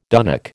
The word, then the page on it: dunnock: American English